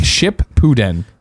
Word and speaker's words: Shippuden: It's pronounced ship pooden.